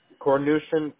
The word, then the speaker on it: Kornyushin: Kornushin, K-O-R-N-Y-U-S-H-I-N.